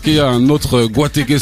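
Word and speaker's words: Guateke: Et sauf que si je le mettais tel quel, de la façon dont ça t'écrit, en France, je pense qu'on aurait prononcé gatec ou un truc comme ça.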